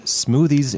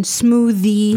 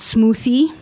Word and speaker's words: smoothie: Now, so let's just touch on the pronunciation, smoothie, not smoothie. – Right. – It's a the sound. – Yeah, it's a voiced, yeah, smoothie.